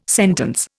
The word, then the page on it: sentence: Indian